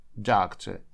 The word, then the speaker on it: doctor: Doctor. Не доктор, doctor. Вот это окончание OR в конце – это безударный звук шва в английском языке. Он читается как а-а-а. Вот так вот коротко.